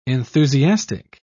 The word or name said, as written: enthusiastic